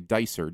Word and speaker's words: Dicer: My last name is actually Dicer, D-I-C-E-R.